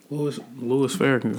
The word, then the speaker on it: Farrakhan: Louis Farrakhan.